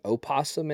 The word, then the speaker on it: opossum: Well, theirs is the possum, and technically our variant is the opossum. Isn't that right? – They're both marsupials. – Okay. – Yeah. Mm-hmm. Yep. – But the O is silent.